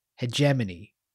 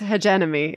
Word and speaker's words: hegemony: It's like having to remind yourself how to pronounce the word hegemony. I did it. I think I did it. – Hegemony. – Hegemony. – Hegemony. But you think it's hegenemy.